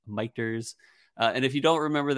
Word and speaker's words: Michter's: And yes, if you're wondering, we are mispronouncing Michter's. It is called Michter's technically.